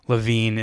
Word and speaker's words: LaVine: I think LaVine is a subtraction by addition in the situation.